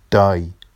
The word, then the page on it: dɑj: Vafsi